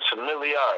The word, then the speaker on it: Familli: My name is Familli.